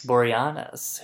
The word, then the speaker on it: Boreanaz: Boreanaz, who would play Angel eventually to get a spin-off.